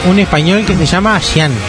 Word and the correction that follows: Jaume: Jaume. Jaume Colet Serra.